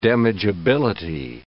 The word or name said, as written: damageability